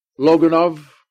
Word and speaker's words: Loganov: Loganov? Yeah, Loganov. OB is Ukrainian. OFF is Russian. Yeah, he's OV.